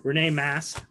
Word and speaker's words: Rene Masse: This week, my guest is Rene Masse.